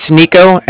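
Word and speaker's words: Niko: It's Nico, N-I-K-O. I'm calling with the Bank of Cardiff, and that's spelled C-A-R-D-I-F-F, as in Frank.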